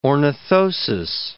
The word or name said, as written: ornithosis